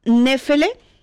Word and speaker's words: Nefele: La primera banda se hace llamar Nefele, con acento en la primera E, y os cuento un poquito de estos chicos.